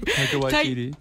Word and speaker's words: Waititi: Taika Waititi. – Waititi. – Is that how you say it? Yeah. Taika Waititi. How do you say his name? – Taika Waititi.